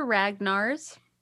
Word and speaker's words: Ragnars: So my name is Heather Ragnars.